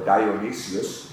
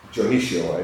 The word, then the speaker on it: Dionisio: I would have probably have pronounced it Dionisio or Dionysius, but Dionisio, I think, is how it's properly said, San Dionisio.